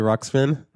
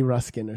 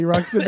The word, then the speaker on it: Ruxpin: Teddy Ruxpin. – You usually say like Teddy Ruskin or something.